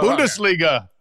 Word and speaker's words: Bundesliga: I learned how to say, but I've since forgotten, Bungaldisha. Is that how you say it? – Bungaldisha. – Bundesliga. Right? – Bundesliga.